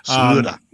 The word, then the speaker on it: Schröder: I'll pronounce it in the German vernacular. – Schröder.